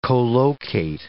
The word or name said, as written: colocate